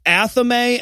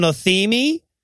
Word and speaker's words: athame: Yeah, no, and he's like, He talks about the knife that the Wiccans use, which is correctly pronounced athame, and he calls it an athemi. And I'm like, you know, everybody mispronounces that. I don't expect him to get athame right, but like, that is the dumbest sounding possible mispronunciation.